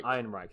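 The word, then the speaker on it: Ehrenreich: Ironreich, thank you for that.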